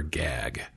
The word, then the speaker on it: GAG: Is it G-A-G or gag? – She calls her gag. It's spelled G-A-G, but it's gag.